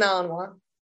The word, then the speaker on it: Malinois: A Belgian Malinois. – A Belgian, how do you say the Malinois.